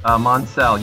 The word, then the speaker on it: Monsell: Is it Monsell? I realize I should have asked you before we started recording. – Monsell. – Monsell. – You pronounced it right the first time. Yeah, okay. – With an S.